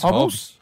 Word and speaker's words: Hobbes: In the Calvin and Hobbits, what's that say? – I think it's Hobbes. I think it's Hobbes. – Oh dear. That's right.